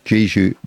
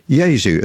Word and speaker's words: Jesu: I've just been told that I should not say Jesu, Joy of Man's Desiring, because if I have a Catholic upbringing, I would know it is pronounced Jesu.